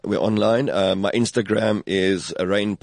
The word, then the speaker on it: Rayne: And that's R-A-Y-N-E. – R-A-Y-N-E, but pronounced rain down on me because I promised to make you wet.